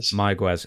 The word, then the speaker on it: Mayagüez: David Flair, he wrestled Ray Gonzalez in Mayagüez. Am I pronouncing that right? – Mayagüez, in a match with fans surrounding the ring with belts.